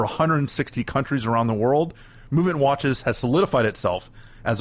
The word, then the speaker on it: MVMT: We'll have more with Josh in just one moment, but let me take a minute to tell you about Movement. Movement Watches, spelled MVMT but pronounced movement, was founded on the belief that style shouldn't break the bank.